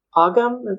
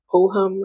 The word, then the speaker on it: Ogham: The um Ogham, that's not how you say it though. It's ohum or something, but the Druidic Celtic deck.